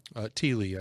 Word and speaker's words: Thiele: I tell you what, Dr. Edwin Thiele. Thiele, I believe, is the way that you properly pronounce his last name, put together a book.